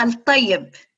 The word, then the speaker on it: El Tayeb: Did I say her name right, Mariam? Fatima El Tayeb. Okay.